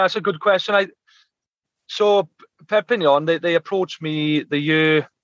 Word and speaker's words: Perpignan: In 2011, you joined French side Perpignan. – Yeah. – How's it pronounced? – Perpignan. – Yeah, so it's a mouthful, that one. Perpignan, some people pronounce it Perpignan, but I know what you mean, don't worry.